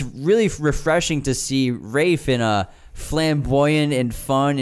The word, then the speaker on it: Ralph: Rafe is such a talented actor, and everybody, it's pronounced Rafe, even though there's an L in there.